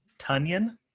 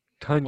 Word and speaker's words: Tonyan: So, first off, I believe his name is pronounced Tonyan. – Tonyan, like an onion, okay.